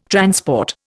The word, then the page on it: transport: Indian